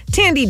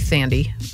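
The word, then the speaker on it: Thandie: Sally Field, Ethan Hawke, Tandy Newton. Sorry for 4 hours calling you Sandy.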